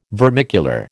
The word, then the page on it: vermicular: American English